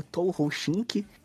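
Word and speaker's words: 東方神起: que no Japão ela é pronunciada como Touhou Shink e na verdade ela tem até outras, ela tem várias pronúncias, né?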